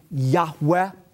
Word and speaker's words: Yahweh: You see, the God's sacred name, Yud-Heh-Vav-Heh, given in the Hebrew Bible, we pronounce a breathy Yahweh.